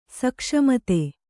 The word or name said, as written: ಸೂಕ್ಷ್ಮಿತ